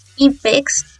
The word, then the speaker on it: EPEX: porque también vamos a hablar de IPEX. Se pronunciaba IPEX, pero se escribe E-P-E-X.